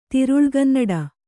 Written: ತಿರುಳ್ಗನ್ನಡ

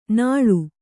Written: ನಾಳು

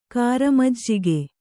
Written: ಕಾರಮಜ್ಜಿಗೆ

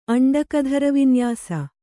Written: ಅಂಡಕಧರವಿನ್ಯಾಸ

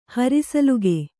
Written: ಹರಿಸಲುಗೆ

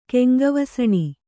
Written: ಕೆಂಗವಸಣಿ

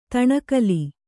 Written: ದುಣಕಲು

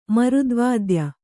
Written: ಮರುದ್ವಾದ್ಯ

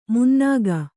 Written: ಮುನ್ನಾಗ

